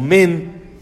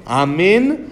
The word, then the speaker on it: amén: o no contestar amén o men, si- sino pronunciar bien amén cuando acaba de, cuando debe ser, acaba la verajá, se contesta el amén, no un poquito antes.